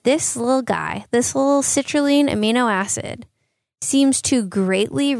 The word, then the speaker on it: citrulline: There's one known as, I believe you pronounce it citrulline. That's how I would do it as a chemist.